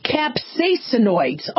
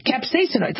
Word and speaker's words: capsaicinoids: Capsaicinoids. Oh, like capsaicin. Yeah, I pronounced that one right. Capsaicinoids.